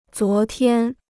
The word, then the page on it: 昨天: zuó tiān